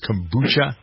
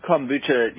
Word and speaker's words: kombucha: Do I pronounce that right? Is that how it is, kombucha? – Yep, kombucha, you know.